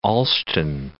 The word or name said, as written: Olsztyn